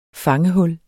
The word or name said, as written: fangehul